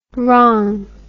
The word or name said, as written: rong